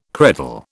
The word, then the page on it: credal: British English